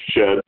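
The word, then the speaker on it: Shed (שד): The Shin and Dalet is pronounced Shed, and it represents demons, is with the literal translation.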